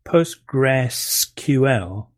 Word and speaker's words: Postgres: it's a really bizarre name, PostgresQL, but it's still pronounced Postgres by most people.